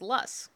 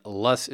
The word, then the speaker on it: loess: what you do see, though, is loess. – Right, and loess is spelled L-O-E-S-S, if you're about to Google that.